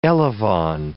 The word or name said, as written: elevon